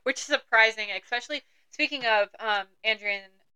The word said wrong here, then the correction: Andarna: I can't say her name correct. – Who? – The dragon. – Andarna? – Andarna.